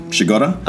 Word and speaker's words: Przygoda: Yeah, and I can say her last name is spelled P-R-Z-Y-G-O-D-A, her maiden name, which is pronunciated Shigoda.